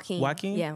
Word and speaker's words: Joaquin: Is that how you pronounce? – Yeah, Joaquin.